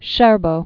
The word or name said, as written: Vitaly Scherbo